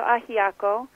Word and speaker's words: ajiaco: But ajiaco, is that how you pronounce the traditional Cuban stew? – Yes, so ajiaco is considered the national dish by many.